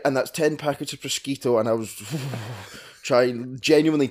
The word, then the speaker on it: prosciutto: What's the Italian for ham? Can you remember? – It's a prosciutto. – Yeah, prosciutto.